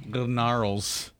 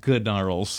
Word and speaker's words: Gnarls: Gnarls Barkley, Crazy. – Gnarls. It's pronounced Gnarls.